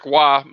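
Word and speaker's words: Roy: Moving on, goaltender Patrick Waugh made his NHL debut for the Montreal Canadiens on February 23rd. – Waugh! – That is how you pronounce that, yeah.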